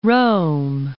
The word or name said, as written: Rome